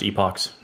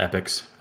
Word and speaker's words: epochs: And epochs is pronounced epochs, not epics, and I will die on that hill.